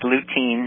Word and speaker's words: lutein: Is it Lutzen and Zeaxanthin? Are you familiar with those things? – You're doing that on purpose. It's lutein and zeaxanthin.